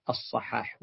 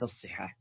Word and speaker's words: الصحاح: الصحاح الصحيح أنها تنطق بفتح الصاد، الصحاح وليس الصحاح.